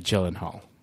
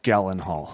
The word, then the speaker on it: Gyllenhaal: It might be Gyllenhaal. – No, it's definitely Gyllenhaal. – The actor goes by Gyllenhaal.